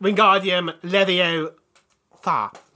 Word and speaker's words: Wingardium Leviosa: It's pronounced Wingardium Leviosa.